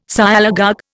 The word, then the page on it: Sialogogue: Indian